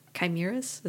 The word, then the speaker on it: chimeras: I guess, like, I like chimeras.